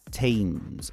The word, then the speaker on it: Thames: The spelling is T-H-A-M-E-S. No, it's not Thames. It's Thames. Basically, the pronunciation is Thames, as if it's a T-E-M-Z, let's say.